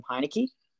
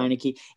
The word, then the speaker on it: Heinicke: Heinicke? – I think so. – Is that how you say it? – Yep, I think so. – Heinicke.